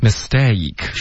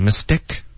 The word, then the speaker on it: mistake: Okay, mistake, 双元音要注意，别发成 mistake.